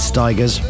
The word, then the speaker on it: Stigers: As the legendary Steve Wright, the late great, used to say, that's Curtis Stigers, rhymes with tigers, and I wonder why, playing on Gen X Radio Suffolk.